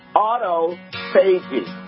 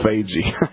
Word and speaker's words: autophagy: Autophagy. It's A-U-T-O. – Autophagy.